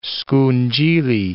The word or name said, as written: scungilli